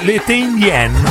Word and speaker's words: L'été indien: Si chiama Summer, o Indian Summer, che poi in versione originale era in francese, L'été Indien. Si pronunciava così.